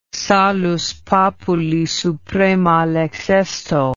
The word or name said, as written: salus populi suprema lex esto